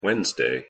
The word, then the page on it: wednesday: English